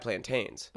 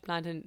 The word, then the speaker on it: plantain: I was frying a bunch of plantain. We call it plantain. But plantain? – Yeah. It is spelt plantain, but in the Caribbean we call it plantain.